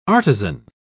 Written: artisan

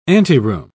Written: anteroom

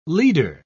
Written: leader

